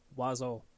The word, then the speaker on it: oiseau: It's like the word oiseau, which is the French word for bird, which has every single vowel in it, and yet you pronounce none of them. It's a seven-letter word, and it's pronounced oiseau.